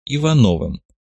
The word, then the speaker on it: Ивановым: Кстати, представлюсь, моя партийная кличка Агасфер, но сейчас меня чаще называют Ивановым. Ударение на втором слоге по-офицерски.